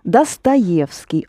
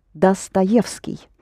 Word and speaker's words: Dostoevsky: Dostoevsky, only don't pronounce do, that's the English way of doing it. Dostoevsky.